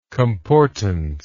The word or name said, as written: comportance